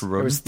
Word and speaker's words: Rodan: pronounced Rodan.